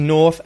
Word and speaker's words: north: So it can seem like you're meant to say that R, but Australian English is non-rhotic. You don't say the R unless there's a vowel after it. So we'll use the long vowel sound or north. North. No R, straight into the TH.